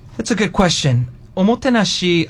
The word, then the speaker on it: omotenashi: Omotenashi? – Omotenashi, yes.